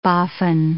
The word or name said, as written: boffin